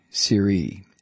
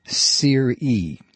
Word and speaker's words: SERE: And apparently we've been pronouncing S-E-R-E wrong. It's Sear-E, Sear-E, S-E-R-E. I've been pronouncing it Sear, and I think most people in media have been pronouncing that way. Sear-E.